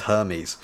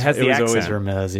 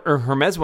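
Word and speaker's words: Hermès: So this Hermes, is that how it's always been pronounced, or did they change it today? Because I always thought it was Hermes. – No, it was always Hermes.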